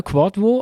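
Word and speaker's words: Kwadwo: Zum Beispiel der Ralf, weil wir haben letztes Mal doch uns äh, gefragt, wie der Quadvo Dua ausgesprochen wird. Quadvo, oder? Genau, Quadvo. Also das, die, die W sind so kurze U, hat mir der Ralf geschrieben. Aber nicht nur das, weil er Quadvo heißt, weiß man auch, dass er am Montag zur Welt gekommen ist.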